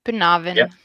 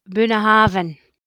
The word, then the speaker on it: Bunnahabhain: Our favourite, Bunnahabhain. – I think I tend to say it like Bunnahabhain. – Okay, yeah, you're both pretty close. So, Bunnahabhain's how I hear people saying it in English.